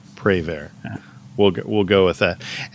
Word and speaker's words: Prévert: This was adapted by the Raincoats from a poem by Jacques Prévert. I don't know if I even said that right. – If it's French, the T is probably silent. – Okay. – That's all I remember Prévert. From French class.